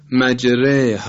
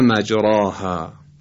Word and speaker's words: مجراها: تلفظ دقیق و صحیح این کلمه در روایت حفص از قرائت عاصم به این نحوی خدمتون عرض میکنه، مجره مجره و نه مجراها. همطور که ملاحظه میفرمایید الف به گونه تلفظ میشه که گویا یک کسره امتداد یافته است.